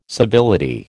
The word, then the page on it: civility: American English